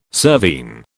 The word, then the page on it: cervine: British English